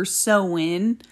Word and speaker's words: Samhain: However, last and not least, we're going to go over Samhain, or Samhain, which is what it's supposed to be pronounced like. I don't know why, because it looks like Samhain, but apparently it's Samhain or Sowin.